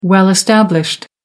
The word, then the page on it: well established: American English